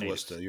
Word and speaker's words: Worcester: Well, I guess you've been away from there for a while, so you pronounce both R's. Or yes, actually, you pronounce the R's at all, I should say, because generally speaking, the R's are not pronounced. – Well, it's pronounced Wista.